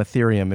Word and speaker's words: Ethereum: Yeah, I expect some Ethereum in my my Coinbase.